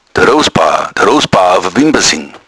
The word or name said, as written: rousbauer